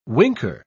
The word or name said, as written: winker